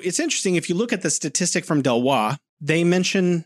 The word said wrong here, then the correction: Deloitte: It's Deloitte. – Is it Deloitte? Oh, I've been mispronouncing it for years, Alex.